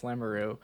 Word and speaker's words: Lamoureux: I was like making sure that I wasn't screwing that up, that there's not other syllables. But yeah. – Yes. – Okay, yes, I'm correct. I got the name in front of me. Yes, Lamoureux.